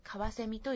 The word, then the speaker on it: 翡翠: 宝石の翡翠と同じ、翡翠と書いてカワセミと読みます。